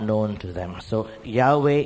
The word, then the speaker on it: Yahweh: We can pronounce the name of God as Yahweh. YHWH, pronounced as Yahweh.